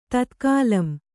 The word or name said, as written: ತತ್ಕಾಲಂ